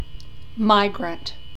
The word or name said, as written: migrant